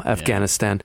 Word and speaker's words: Afghanistan: Americans typically put the emphasis on the second syllable, Afghanistan.